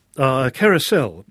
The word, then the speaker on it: Carousel: I don't know what piece we've got Carousal here. – Carousel, actually.